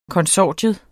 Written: konsortium

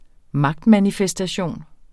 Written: magtmanifestation

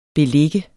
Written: beligge

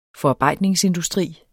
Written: FORARBEJDNING

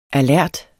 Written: alert

